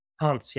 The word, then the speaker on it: Hans: Hans, yeah.